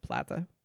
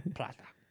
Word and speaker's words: Plata: the longest one is the Via de la Plata. I know I'm saying that wrong. – Plata.